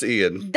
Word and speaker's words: Ion: Yeah, it's Ion. Like, how you would spell the word Ion. – But it is pronounced Ian.